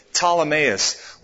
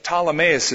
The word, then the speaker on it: Ptolemais: The Bible says in the book of Acts that Paul the Apostle stopped at a place called Ptolemais, P-T-O-L, it's spelled initially, Ptolemais, where there were some brothers in Christ who spent a few days with them. Ptolemais is here.